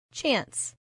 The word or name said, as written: chance